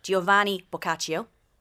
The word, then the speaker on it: Giovanni Boccaccio: Giovanni Boccaccio. Yeah, okay. Perfect. I think that was flawless.